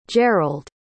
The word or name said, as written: gerrald